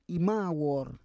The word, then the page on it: imawor: Roon